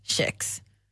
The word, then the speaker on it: kex: Nej, det var faktiskt inte Fredrik Lindström säger, om vi fortsätter läsa den här artikeln då, precis som orden kedja, kela och keramik så ska alltså kex uttalas kex. Som jag måste välja så uttalas det kex.